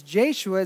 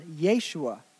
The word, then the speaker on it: Yeshua: Is Jeshua is actually pronounced Yeshua.